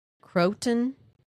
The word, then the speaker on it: Croton: One observer watched the UFO over Croton.